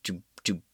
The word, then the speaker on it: Debicki: How do you say her last name? Let me see. Her last name is Debicki. Debicki. Elizabeth Debicki. I was like, I know I've seen her somewhere.